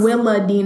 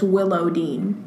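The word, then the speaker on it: Willowdean: not it's, it's not, not, Willa Dean. – It's Willow Dean.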